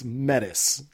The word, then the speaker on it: Metis: it rhymed with fetus or it was like a silent S, like me too, or I, am glad it's it's Metis, like metal.